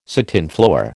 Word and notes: satinflower: American English

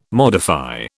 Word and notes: modify: British English

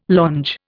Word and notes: longe: Indian

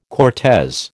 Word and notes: Cortes: American English

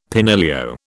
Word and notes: penillio: British English

